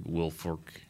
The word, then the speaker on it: Wilfork: Did Vince Wilfork, which I never say his name right, Wilfork. – Yeah, that sounds right.